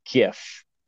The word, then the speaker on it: GIF: How do you pronounce that? – That would be a GIF. Like a GIF without the T.